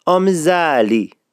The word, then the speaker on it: آمیزالی: چی میگن اصفهانی ها؟ میگن آمیزالی. تکرار میکنم، آمیزالی.